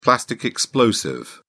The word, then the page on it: plastic explosive: British English